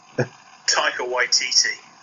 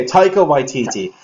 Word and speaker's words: Taika Waititi: Taika Waititi. – Okay, Taika Waititi.